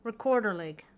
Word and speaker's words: Rekorderlig: It is, I'm going to do my best here, Rekorderlig. Rekorderlig. Someone right now is shaking their head and just pronouncing it correctly, so I apologize. – Rekorderlig. – Yep, that's exactly what it is.